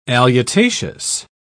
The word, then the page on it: alutaceous: British English